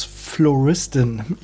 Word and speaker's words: Flowristin: Nun habe ich dich ja vorstellen dürfen als Floristin. Ich hoffe, das ist richtig ausgesprochen. Ja.